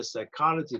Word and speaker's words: psychology: 那另外一个字也代表生命，那叫做 Psyche，那就跟英文的 psychology 那个字的字根从这里来了，只是我们英文的 psychology 的时候，那个 p 在前面不发音，就是 psychology。